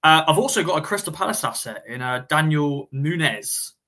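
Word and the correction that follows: Muñoz: That's how you say it. – Munoz. – Munoz. – There's no E at the end of that, I don't think. – Yeah. No, it isn't. – M-U-N-O-Z. – Munoz. – Yeah, I'll give you Munoz.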